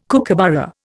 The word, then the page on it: kookaburra: Indian